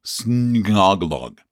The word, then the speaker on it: Sgnolg: It's pronounced snoglog, with a G being silent like in gnu, you know, the animals, the gnu. It's S-N, I'm sorry, I did that wrong. It is S-G-N-O-L-G, snoglog.